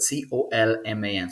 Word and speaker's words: Colman: It's my name, but you spell it C O L M A N.